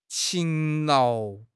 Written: 勤劳

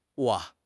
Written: wo3

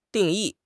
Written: 定义

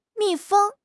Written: 密封

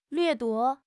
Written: 掠夺

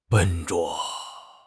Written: 笨拙

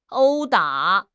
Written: 殴打

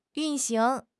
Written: 运行

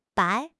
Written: bai3

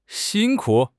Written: xin1 ku3

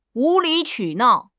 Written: 无理取闹

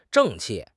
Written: 正气

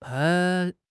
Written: pai1

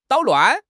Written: 捣乱

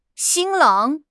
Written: 新郎

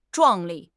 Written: 壮丽

